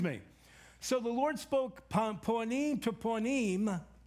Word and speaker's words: panim: It's the word ponim, P-A-N-I-M, but we pronounce it ponim.